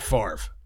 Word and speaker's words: Favre: Any English professor, explain Brett Favre. The V is before the R. – Favre. – But it's Favre.